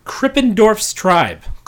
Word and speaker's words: Krippendorf's Tribe: It is pronounced Krippendorf's Tribe.